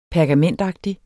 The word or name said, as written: pergamentagtig